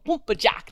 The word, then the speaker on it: Pumpa Jack: They're like, yeah, there are these fish called Pumpa Jack. That's how they say it. Pumpa Jack.